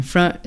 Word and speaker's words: Froning: I watched a couple years ago, Frowning? – Froning? – Is that how you say it? – Yeah.